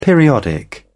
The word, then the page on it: periodic: British English